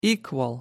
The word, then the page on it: equal: American English